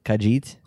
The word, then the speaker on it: Khajiit: Part of the Season of the Dragon year-long adventure, explore the savannas and canyons of Elsewhere, defend the home of the awesome, how do you pronounce this? Khajiit. – Yes. Hey, you got it. That was actually good.